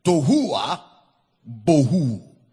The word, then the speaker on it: tohu wa bohu: Tohua bohu is spelled as T-O-H-U-W-A-B-O-H-U.